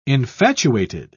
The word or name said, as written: infatuated